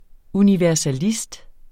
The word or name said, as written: universalist